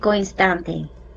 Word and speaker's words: Instante: Instante. – So it's spelled the same way as Eco Instant, but it is pronounced with the Spanish Ego instante.